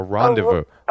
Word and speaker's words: rendezvous: A rendezvous. No, you said it correctly.